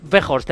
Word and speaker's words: Weghorst: Weghorst, ¿cómo se dice así? – Weghorst, el delantero.